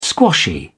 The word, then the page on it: squashy: British English